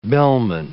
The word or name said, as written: bellman